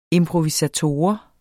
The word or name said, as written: improvisator